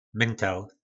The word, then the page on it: myntel: po nasimu